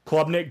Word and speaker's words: Klubnik: And, is it Klubnik or Klubnik? – It's Klubnik.